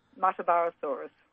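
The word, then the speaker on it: Muttaburrasaurus: Mutterburrasaurus.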